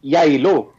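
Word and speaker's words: Geilo: She gathered her family in a ski resort in a town of Gilio? How do you say that? – Gilio. – There you go. I wasn't even close.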